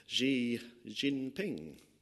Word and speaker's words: Xi Jinping: The second one, I'm sure you know who he is, one of the most powerful leaders in the world, Xi Jinping. Did I pronounce that right, Phil? Good, yeah. My Mandarin expert over there.